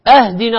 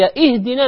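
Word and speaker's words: اهدنا: أهدنا الصراط، أهدنا من الهدية، لكن هي إهدنا من الهداية.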